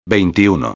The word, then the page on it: 21: espanhol